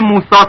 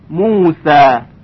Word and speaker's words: موسا: خوب دقت کنید در کلمه صالحین که با صاد نوشته میشه به خاطر استعلاء و اسباق حرف صاد صدای اون خیلی درشت شنیده میشه. صالحین، صالحین. اما در کلمه موسا توجه کنید حرف سین به خاطر داشتن صفات استفال و انفتاح با صدای ظریف‌تری تلفظ میشه. س، موسا، موسا. حالا دو مرتبه مقایسه میکنیم. ص، صالحین، صالحین. س، موسا، ص و س. پس صدای حرف صاد درشتر از صدای حرف سین هست.